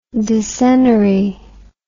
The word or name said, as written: decenary